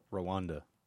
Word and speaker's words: Rwanda: Yes, Rwanda.